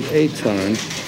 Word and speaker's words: Etan: Well, they pronounce it Eitan.